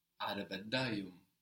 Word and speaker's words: Arabadayım: Arabadayım. And there you pronounce the I without the dot, no?